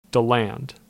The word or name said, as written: DeLand